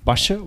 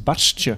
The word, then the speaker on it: Barstje: Ehm, we dachten trouwens eerst dat we gehoord hadden dat het ehm, Basje was, maar het is Barstje.